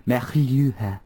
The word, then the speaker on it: Melua: Melua.